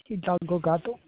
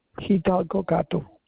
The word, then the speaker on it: Hidalgo Gato: Xiomara Hidalgo Gato.